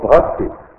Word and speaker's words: бхакти: Итак, причина, по которой можно получить бхакти, слово бхакти, бхакти, ну, ха, вот она не читается, бхакти означает любовь и преданность.